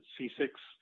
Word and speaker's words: CCIX: They pronounce it C6, and also OpenCAPI.